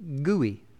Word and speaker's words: GUI: G-U-I, and it is pronounced gooey.